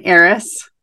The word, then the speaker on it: heiress: So did you say, like, she's a heiress? – An heiress. – Okay. It's a silent H.